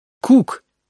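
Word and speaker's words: Cook: «Это пишется «коок», а читается «кук» по-английски.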